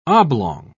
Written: oblong

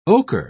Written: ocher

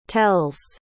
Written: tells

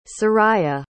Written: Seraiah

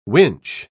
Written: winch

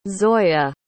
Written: zoya

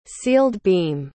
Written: sealed-beam

